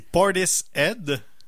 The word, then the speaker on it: Portishead: c'est Portis Ed, Parce que le mot head en anglais, c'est quelque chose.